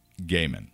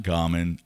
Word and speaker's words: Gaiman: So I saw a little piece of video of Neil Gaiman pronouncing his name. – Oh, really? – And it's Neil Gaiman. – Okay. – You know, not Gaiman, all this.